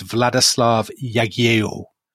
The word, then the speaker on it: Władysław Jagiełło: So Poland and Lithuania have the same king, who is Władysław Jagiełło.